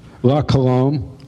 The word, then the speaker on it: La Colombe: La Colombe.